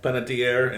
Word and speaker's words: Panettiere: Panettiere, and Ryan Gosling.